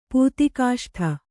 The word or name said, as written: ಪೂತಿಕಾಷ್ಠ